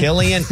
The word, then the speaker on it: Cillian: Killian. – Kelly? – Yeah. – You do that all the time. – Because it was the most mispronounced name of 2023.